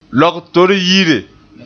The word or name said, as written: logtor-yire